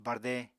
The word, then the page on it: barde: Apatani